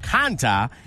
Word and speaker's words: Konta: beating Johanna Konta and Magdalena Rybarikova.